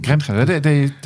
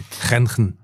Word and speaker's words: Grenchen: And I learned last week I was in Grenchen, as they say, I guess, in Switzerland. – Never heard anybody say Grenchen. – Grenchen. They pronounce it like we do in the Netherlands as well.